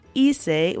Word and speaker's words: hice: so in this case it's hice, which is spelled H-I-C-E, but the first syllable starts with E.